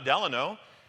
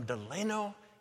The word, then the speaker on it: Delano: I'm from Delano, California, pronounced Delano, not Delano.